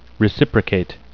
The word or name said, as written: reciprocate